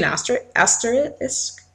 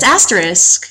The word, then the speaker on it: asterisk: There actually being asterisk beside it? – Good pronunciation. – What is it? It's asterisk. – Asterisk. – Asterisk. – There you go. You got it.